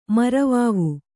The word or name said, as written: ಮರವಾವು